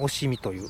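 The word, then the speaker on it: 忍海: おしみという、しのぶ海と書いて、はい、おしみと言いますが、はい、おしみ地方から出てきた釘と同じタイプのものだということが、はい、分かったわけですね。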